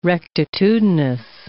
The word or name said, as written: rectitudinous